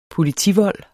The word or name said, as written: POLITIVOLD